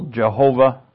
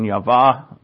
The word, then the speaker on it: Yahweh: what would be what we call Jehovah, which is actually the wrong pronunciation, though we have noted that the pronunciation, how you pronounce it, doesn't matter. It's you knowing what that name represents. That means everything. I like the pronunciation Yahweh. That's more closer to probably what it was. Yahweh is probably closer as well.